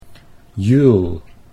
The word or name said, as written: Juul